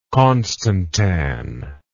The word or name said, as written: constantan